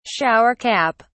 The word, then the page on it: shower cap: British English